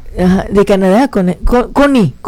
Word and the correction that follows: Coné: ¿no? – Coné, sí. Coné. Es que tiene acento en la E.